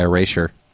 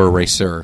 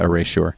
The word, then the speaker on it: Erasure: but it's actually a song by Erasure, or Eraser. I don't know if how they actually pronounce it. But I think you had it right the first time. – It's spelled like sure, yeah, Erasure.